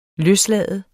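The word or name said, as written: løslade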